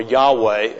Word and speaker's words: Yahweh: But his proper name, his personal name, his name par excellence is Jehovah, or Yahweh, as scholars prefer to pronounce it.